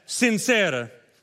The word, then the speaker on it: sincera: Everybody say it like that, sincera.